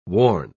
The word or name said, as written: worn